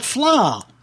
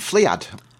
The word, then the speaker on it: Fleadh: coming up to the last track now, which is another bit of tunage from a band called Flaw. – And I'm glad you said that. – That is spelled F-L-E-A-D-H. – I was going to call them Fliad.